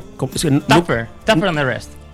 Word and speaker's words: Tougher than the rest: Together Down the Rest es. No, tougher. – Tougher than the rest.